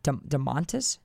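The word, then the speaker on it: Domantas: Moving on to DeMontis.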